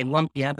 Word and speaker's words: lumpia: Sometimes people say lumpia, but it's pronounced lumpia, which is like sort of a spring roll or Filipino spring roll.